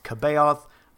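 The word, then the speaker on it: C'baoth: That dude's name is pronounced Kabaoth. I will never in my life say the soft sea.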